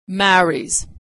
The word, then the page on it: marries: z